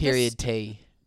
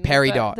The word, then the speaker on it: peridot: Period T. – Period T. Yeah, no. You're thinking about the stone. – Peridot.